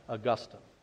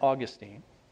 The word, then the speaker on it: Augustine: Notice how I pronounce that, Augustine. Now, you could pronounce that Augustine.